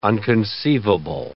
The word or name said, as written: unconceivable